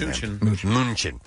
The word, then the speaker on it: Mnuchin: Meanwhile, House Majority Leader Nancy Pelosi and Treasury Secretary Stephen Mnuchin, or no, M- I don't know how to say it. – Mnuchin. – Thank you.